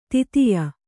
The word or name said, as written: ದೈತೇಯ